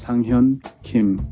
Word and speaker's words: Sanghyun Kim: It's very, very similar, but in Korean, there's a very, very distinct, you know, pronunciation there. So my name is Sanghyun Kim.